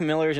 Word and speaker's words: Millar: Mark Millar, you got to pronounce his name right. – It's Miller. – Bitch, nothing. – I have heard the guy pronounce it himself.